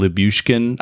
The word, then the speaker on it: Lyubushkin: I just want to point out that I spelled Lyubushkin. I might not be pronouncing it correctly. – No, I think you're right.